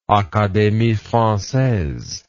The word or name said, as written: academie francaise